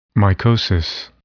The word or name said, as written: mycosis